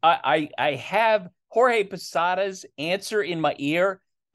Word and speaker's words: Jorge: You know, a number of his of Jorge Posada's teammates would refer to him as George or Georgie. And that, and I remember asking Posada, like, how do you like your name pronounced? And he was so specific, and you could hear that it was important to him. I like being called Jorge.